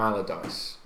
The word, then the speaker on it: Allardyce: This week's situation, I don't know a lot about it, is Sam Allardyce. Is that how you say it? – Yep.